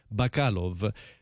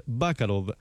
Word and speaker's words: Bacalov: Alcuni pronuncerebbero Bacalov, però comunemente viene chiamato così, anche fuori dall'Italia, Luis Bacalov, che è stato un pianista, compositore, direttore d'orchestra e arrangiatore argentino, naturalizzato italiano.